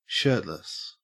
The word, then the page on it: shirtless: Australian English